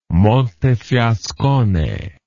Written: Montefiascone

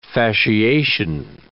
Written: fasciation

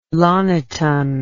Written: Loniten

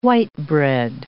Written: white-bread

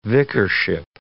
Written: vicarship

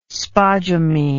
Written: spodumene

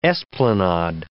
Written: esplanade

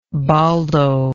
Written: boldo

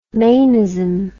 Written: manism

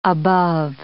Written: above

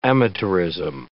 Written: amateurism

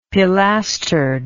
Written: pilastered

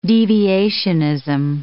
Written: deviationism

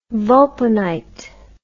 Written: vulpinite

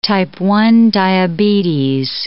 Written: type 1 diabetes

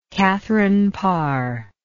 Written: catherine parr

